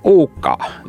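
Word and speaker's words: Ukko: Ука, да? И иногда этого... – Хорошее финское произношение.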